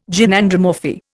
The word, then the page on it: Gynandromorphy: Indian